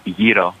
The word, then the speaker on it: gyro: the proper pronunciation would be gyro.